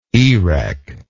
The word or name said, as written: erech